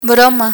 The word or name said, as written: broma